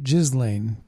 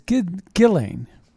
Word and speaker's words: Ghislaine: And so we have, well, Ghislaine, I think is the way you pronounce it. I've been pronouncing it Ghislaine. But it's Ghislaine, according to her attorney.